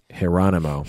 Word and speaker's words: Geronimo: So think Geronimo, but with a J, pronounced Geronimo.